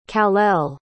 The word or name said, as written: kalel